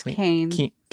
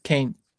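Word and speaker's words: Kean: It's Keene. – Keene. Sorry. – It's okay. – She's one of my heroes, actually. Like, I love her, but I apologize for messing up her name. – That's okay. It's spelled a certain way and it causes people to be confused.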